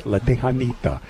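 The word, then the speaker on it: La Texanita: Remember, it's spelled with an X, but don't pronounce the X. You'll sound like a gringo if you do. It's La Tejanita.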